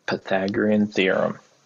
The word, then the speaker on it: Pythagorean theorem: You got the Pythagoras theorem. Am I saying that right? I can't pronounce words. Why do I podcast? – Pythagorean theorem.